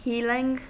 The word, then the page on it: healing: American English